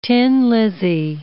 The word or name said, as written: tin lizzie